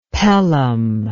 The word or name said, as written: Pelham